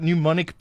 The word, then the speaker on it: pneumonic: While we're talking about something that's worrying, should we be worried about pneumonic plague? There's a new word, and it's got a P and an N. So you got the P is silent.